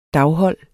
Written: daghold

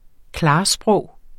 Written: klarsprog

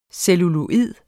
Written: CELLULOID

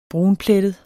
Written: brunplettet

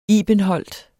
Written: ibenholt

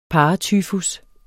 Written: paratyfus

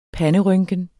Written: panderynken

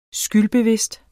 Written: SKYLDBEVIDST

